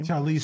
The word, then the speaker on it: Charlize: Charlie Theron, I always forget how to pronounce her last name. – Charlize Theron.